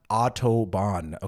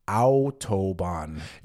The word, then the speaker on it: Autobahn: It is not Autobahn, okay? Like, not the, like the name Otto, O-T-T-O, okay? It is pronounced Autobahn.